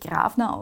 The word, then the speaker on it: Gravner: Also es kann gut sein, dass der Name in irgendeiner Form dann von irgendwas Österreichischem übergeschwappt ist man spricht sie wirklich Grafner aus, also so wie man es auf Deutsch auch sagen würde.